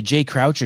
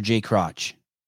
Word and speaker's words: Jay Crouch: Is it Jay Crouch or Jay Crotch? – I think it's Jay Crouch.